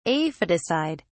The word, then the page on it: aphidicide: British English